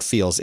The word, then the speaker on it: Feals: So go check out Feels. It is spelled F-E-A-L-S, Feels.